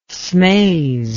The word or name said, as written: smaze